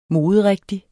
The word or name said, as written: moderigtig